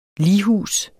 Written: lighus

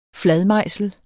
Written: fladmejsel

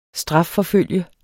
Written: strafforfulgt

